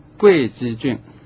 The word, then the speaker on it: 会稽: 在读这篇文章之前，有一个词语的读音我在这说明一下，就是会稽郡，这个会稽的会，有的词典上它注的音是贵，这样就读成贵稽郡。